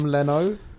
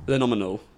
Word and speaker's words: Lenominal: Phenom Leno? – No, it's Leno is the first bit. Oh. – Lenominal.